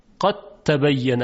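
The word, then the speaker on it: قد تبيّن: ببینید دیگه هیچ اثری از دال وجود نداره، قد تبین حرف تی مشدد هست یا تا مشدد. پس تلفظ دو حرف که دال و تا باشه با صدای حرف دوم که تا باشه با تشدید، قد تبین الرشد من الغی.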